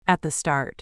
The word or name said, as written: at the start